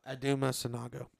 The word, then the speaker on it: Sanogo: Is it Sanogo? – Aduma Sanogo.